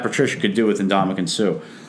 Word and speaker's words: Suh: I'd certainly also take a flyer on the guy whose last name rhymes with glue also, and Ndamukong Suh.